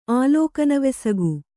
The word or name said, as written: ಆಲೋಕನವೆಸಗು